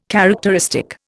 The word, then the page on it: characteristic: Indian